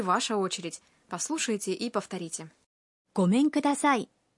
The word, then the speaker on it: ごめんください: Гомен кудасай. – Гомен кудасай означает «здравствуйте» или «прошу прощения».